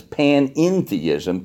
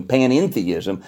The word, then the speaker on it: panentheism: They put one more syllable in there, an E-N in the middle. It's panentheism, panentheism. And panentheists will say, well, you pantheists are not quite right.